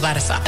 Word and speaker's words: Barça: La bellísima C trancada, que podemos encontrar en palabras como dolç, dulce, llaç, lazo, o Barça. También se usa en francés y en portugués, y en catalán se pronuncia como una S.